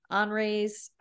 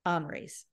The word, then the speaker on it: Henri's: And so it's also near one of my favorite places to eat lunch in Atlanta, Henri's. I pronounce it and she laughs at me. It's Henry's, but with an I, and it's French. But they have great, Henri's.